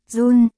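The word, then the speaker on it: jun: 2, run, đọc là, run, là đơn vị đo năng lượng được sử dụng trong hệ đo lường quốc tế SI.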